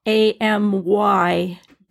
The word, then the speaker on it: amygdala: I'm going to spell it for you: AMYGDALA, and it's pronounced amygdala.